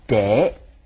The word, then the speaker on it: 茶: In the Minnan dialect, cha is pronounced de.